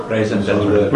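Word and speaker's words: raison d'être: Did I pronounce that right? – I think had some for dinner. – Raison d'etre. – Yeah, had some for dinner. Okay, you must have eaten in a French restaurant, right? How do you pronounce it? – Raison d'etre.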